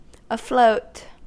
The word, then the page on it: afloat: American English